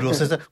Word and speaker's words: Worcester: Elwood Worcester, enfin, ouais, Elwood Worcester, comme la sauce qui va sur le, oui, c'est...